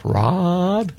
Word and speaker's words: Raad: R-A-A-D. What's the pronunciation of that? Raad? – Yeah, Raad.